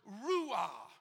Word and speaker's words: ruah: In the original Hebrew language, when the scripture spoke of the breath of God, the word is ruah, R-U-A-H. You pronounce it however you want. I'm calling it ruah.